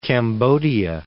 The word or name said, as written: Cambodia